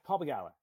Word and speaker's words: Begala: I think it's Paul Begala. Yeah. Did I say that? Yeah, Paul Begala.